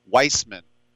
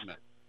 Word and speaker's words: Weissman: I have noticed, if you don't mind me saying so, that you call him sort of the more Jewish Weissman. He's oh, actually how is it pronounced? Wiseman. – Wiseman, as in uh, as in the three wise men.